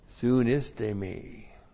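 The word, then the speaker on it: Soon iste me (συνίστημι): S-U-N, that's the preposition soon with. That's been prefixed to the verb histe me. Oop, what am I writing in Greek for? I-S. T, long E, M, I.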